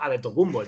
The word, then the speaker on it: Antetokounmpo: Pero realmente la pronunciación correcta sigue siendo adetocumbo.